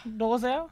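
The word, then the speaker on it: Dolezel: So it's like the Dole food cup. and then like Dozel. You know, like Zelle, like the transfer money from like Chase Bank and like Capital One. Except for like the E-L at the end.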